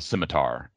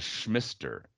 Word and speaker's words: scimitar: Like for instance, you can buy a sword later on called the scimitar, and that's a very particular kind of sword. And now I know how to say the word. When I was young, I would just call it the schmister. And like if you look at the letters, it doesn't, the scimitar doesn't have any resemblance to the sound schmister.